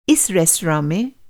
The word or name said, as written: इस